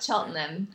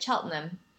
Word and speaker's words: Cheltenham: I don't think I said that right. – You definitely did not. Cheltenham, but like people say it really fast here, so it's just like Cheltenham. So Cheltenham. – That's how the locals say it.